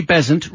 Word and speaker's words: Besant: Laurie Besant rhymes with pheasant in Gloucester. Thank you, Lorraine, for putting the pronunciation in there.